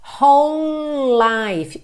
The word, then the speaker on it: whole: Whole, atenção à pronúncia dessa palavra, tá? Porque é bem diferente do que aparenta quando a gente lê. Então, ó, W-H-O-L-E, whole, whole, whole life. Eu junto tudo aqui porque é como se eu tivesse dois L's ligados, já que o E do whole não é pronunciado, tá?